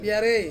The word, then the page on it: piarei: Ansus